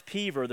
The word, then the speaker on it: Peover: It's spelled P-E-O-V-E-R if you want to look it up later, but it's pronounced Peaver.